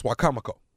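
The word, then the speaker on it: Wicomico: And then Saturday, October the 5th, Wacomico Civic Center. That's Salisbury, Maryland. Am I saying it right? Salisbury. Salisbury, Maryland. – Was you trying to say Wachovia? – No, it's Wacomico.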